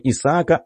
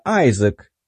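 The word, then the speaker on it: Isaac: Русские произносят мое имя немного на другой манер, не Исаака, Айзек.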